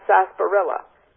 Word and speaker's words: sarsaparilla: Look at that R in there. I thought, is that really sarsaparilla or not when I saw it spelled? And so how about the pronunciation? Oops, I keep trying to push the wrong button. Well, it is good old sarsaparilla. And so we don't have to say that R.